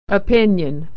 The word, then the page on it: opinion: /y/